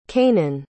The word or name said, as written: cainan